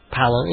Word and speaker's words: Palance: And he opened the album with a cut, for no particular reason, of The Mighty Sparrow, doing a song called Jack Palance, or Palance. He pronounces it Palance.